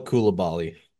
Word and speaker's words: Coulibaly: I was like, Bilal Coulibaly. – yeah, there you go. – Wyatt is known for the incredible pronunciations. – Yeah. Bilal Kulalubi.